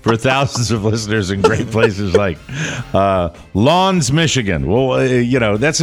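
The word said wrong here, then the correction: L'Anse: Is it French? – It is French. And the listener provided a pronouncer. I said, well, that can't be right. And I did Lons. The research. Lons. Lons. Yeah.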